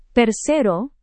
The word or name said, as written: persero